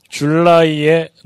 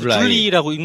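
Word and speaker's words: July: 줄라이에 녹음. 아, 줄라이. – 줄리라고 읽는 거 아닙니까? 네. – 영어 공부를 해야 됩니다. – 이태리에서는 줄리라고 읽던데. – 줄라이를 줄리로 발음을 잘못하면 큰일 납니다.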